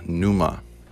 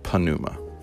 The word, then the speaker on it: pneuma: In Greek, the word is pneuma, or as some pronounce it, panuma. In English transliteration, pneuma would be P-N-E-U-M-A.